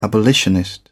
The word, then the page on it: abolitionist: British English